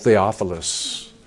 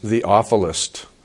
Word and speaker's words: Theophilus: And those of you who love God, we can be called Theophilus, lovers of God. And that's who this wonderful gospel is written to, God lovers. Mike McIntosh also says it can be pronounced Theophilist.